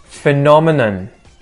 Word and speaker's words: phenomenon: Phenomenon. Phenomenon. Phenomenon. Notice the emphasis.